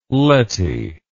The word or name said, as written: Lettie